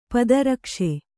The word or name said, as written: ಪಾದರಕ್ಷೆ